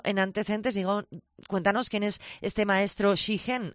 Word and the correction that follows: Shigen-ji: así, no sé si lo he pronunciado bien, pero... – Shigen-ji. – Shigen-ji. Eso es, que le faltaba el ji.